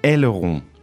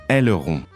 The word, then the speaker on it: aileron: Aileron. – Yeah, let's do some pronunciation because I've always found that word hard to say. – Aileron. – Aileron.